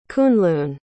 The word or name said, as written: Kunlun